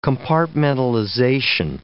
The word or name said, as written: compartmentalization